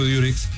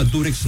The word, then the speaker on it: Durex: así se llamaba Durex, no Durex. Lo que pasa es que nosotros pronunciamos, al decir Durex, pronunciamos la U como se pronuncia en inglés, U, okay. ¿No? Durex le llamamos.